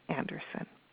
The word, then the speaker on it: Anderson: Anderson. My name is spelled C-A-R-O-L, and then A is my middle initial, no dot though, Anderson, A-N-D-E-R-S-O-N.